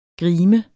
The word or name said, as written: grimes